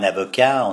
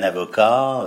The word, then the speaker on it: avocat: On ne dit pas un avocat, on dit un avocat, euh, une cave, les jeunes, les feuilles, voilà, ça c'est les voyelles.